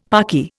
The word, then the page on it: parky: Indian